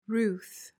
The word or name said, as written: ruth